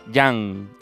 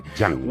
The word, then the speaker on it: Jan: Que se va a llamar Jan y se pronuncia Yang, ah, Con, Y porque se escribe con J, ¿vale? Yang.